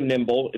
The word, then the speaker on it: Nymbl: and you can search for Nimble. It's spelled N-Y-M-B-L.